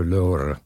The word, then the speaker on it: Lorre: You Americans pronounce it Lorre. – Yes, we Americans. – It is Peter Lorre. – Peter Lorre. – Lorre? – Lorre. Two Lorre. Syllables.